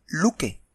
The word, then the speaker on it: Loki: Por cierto, curiosamente, en los idiomas escandinavos, eh, clásicos, el sueco, noruego y el danés, su nombre es Loki y se pronuncia Luke.